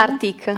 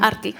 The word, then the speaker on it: Arteak: Pronuncia bene tu? Artic. Artic, l'ho pronunciata bene anche. io.